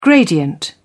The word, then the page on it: gradient: British English